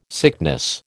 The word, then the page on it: sickness: American English